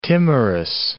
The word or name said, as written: timorous